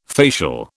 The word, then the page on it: facial: British English